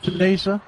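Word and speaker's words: Cenizo: How do you pronounce it? – Right, Seniso.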